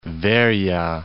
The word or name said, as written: Veroia